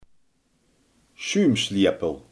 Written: schuimspaan

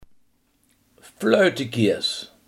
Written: hangop

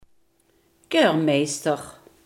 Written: keurmeester

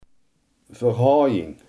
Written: verhouding

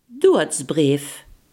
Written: rouwbrief

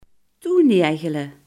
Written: dichtspijkeren

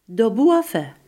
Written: daarboven

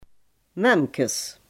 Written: meisjesborsten

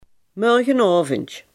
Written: morgenavond

